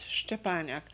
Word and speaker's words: Szczepaniak: Yes, Stepaniak. S-Z-C-Z-E P-A-N-I-A-K, Stepaniak.